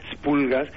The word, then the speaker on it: espoulgas: Se escribe espoulgas, aunque se pronuncia espulgas.